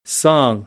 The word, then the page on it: song: American English; ɑː